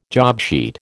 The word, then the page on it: jobsheet: American English